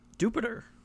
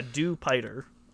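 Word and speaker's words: Dewpider: I would have assumed Dupiter as well before watching the anime, but yeah, it's Dupiter, like Jupiter, and it's... – But not spelled like Jupiter. – Not spelled like Jupiter. It's spelled like, yeah, Dupiter, which...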